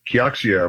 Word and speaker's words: Kioxia: The semiconductor spin out of Toshiba is worth, Kioxia, I believe is the correct way to say it, now worth much more than the parent company Toshiba ever was worth.